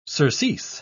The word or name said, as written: surcease